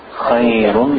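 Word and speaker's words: Khayrun (خيرٌ): Khayrun min al-nawm. Khayrun. Good, good. Khayrun, he's saying it right, just the thickness, a little bit thicker.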